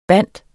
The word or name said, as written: binde